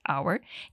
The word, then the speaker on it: our: Tem gente que fala hour com a mesma pronúncia de horas em inglês, hour, e tem gente que diz our.